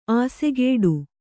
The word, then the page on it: ಅಸುಗೆಡು: asugeḍu